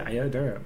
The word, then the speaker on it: Ayoade: Ayoade. And it says here, Ayoade.